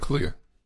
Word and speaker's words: Kaluuya: Daniel Kaluuya. – Okay. Kaluuya.